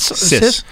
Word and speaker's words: cis: Kiss? – Cis. C-I-S. – Soft C. And then the I is I. – Yeah. Like cellophane. Cis.